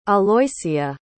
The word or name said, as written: aloysia